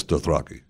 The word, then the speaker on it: Dothraki: Dothraki? – Yes, Dothraki.